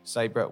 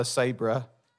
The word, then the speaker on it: Sabré: Sabré, welcome to the Motormouth podcast.